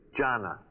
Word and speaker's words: Jana: Jonna. – Jonna. – But it's spelled J-A-N-A, right? – Right.